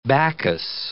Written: Bacchus

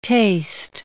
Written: taste

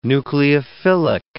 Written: nucleophilic

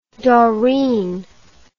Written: dorine

